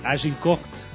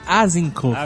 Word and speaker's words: Agincourt: Agincourt. Mas dizem que é Azincourt.